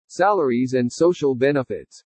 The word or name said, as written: SALARIES AND SOCIAL BENEFITS